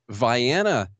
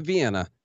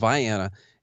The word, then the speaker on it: Vienna: So basically the Kentucky part of Illinois, a little town called Vienna is how we pronounce it, not Vienna, like those crazies over there. It's called Vienna, and also split my time in another town called Pulaski.